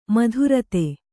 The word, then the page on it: ಮಧುರತೆ: kannada